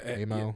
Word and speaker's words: Amo: And, you know, then they went and they did AMO. I think that's how you pronounce it. – Yeah, AMO. – AMO.